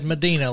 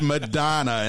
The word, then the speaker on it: Medina: Because I would have said Medina like the Funky Cold variety. – No, it's not Funky Cold Medina. It's Medina, and I can tell you because I spent a year at Lambeth College in Jackson, which is now defunct.